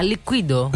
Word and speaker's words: Liquido: tedeschi Liquido. Prova a dirlo tu, com'è che si chiamano questi? – Liquido. – No, cadi sulla I l'accento liqui. – Ah, Liquido.